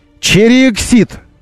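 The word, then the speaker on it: Chery Exeed: А сам бренд в России должен произноситься так. Chery Exit.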